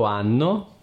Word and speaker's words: hanno: L'H in italiano non si pronuncia all'inizio delle parole. Loro hanno e la parola anno si pronunciano allo stesso modo, quindi l'H non fa differenza nel suono.